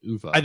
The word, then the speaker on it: Uwe: I think Germans actually pronounce it Uwe. – That wouldn't surprise me. But I think it was always pronounced here Uwe Krupp, wasn't it? – Uwe Krupp.